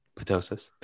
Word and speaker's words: ptosis: I think it's procto, procto, ptosis. Ptosis. The P is silent.